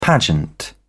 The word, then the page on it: pageant: British English